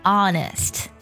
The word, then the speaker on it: honest: 但是在有一些元音前面呢， H 是不发音的，这就属于特殊情况了。比如 Honest 就字母 O 前面，是不是它表示的是诚实的意思。